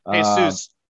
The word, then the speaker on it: Jesus: Also, as a Jew, there is a TikTok where Jesus, is that how you say his name? I don't think I've ever said it before. – Jesus.